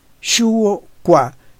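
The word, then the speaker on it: Shuo Kua: Shuo Kwa, que se escribe S-H-U-O separado K-U-A. Discursos sobre los triagramas parte A. En la octava ala, denominada Shuo Kwa, se realiza una disertación sobre las evidencias asociadas de los triagramas.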